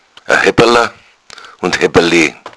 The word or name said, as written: hebbala